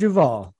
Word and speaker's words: Duvall: His name is Robert Duvall. – Yes, sir. – I know that's the right pronunciation. I'm pretty sure about it.